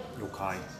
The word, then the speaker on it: Youkai: This mate, did I say that right? Or is it Yokai? – Youkai.